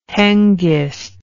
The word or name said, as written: hengist